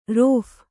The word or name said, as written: ರೋಫು